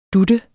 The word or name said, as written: dut